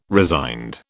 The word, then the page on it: resigned: British English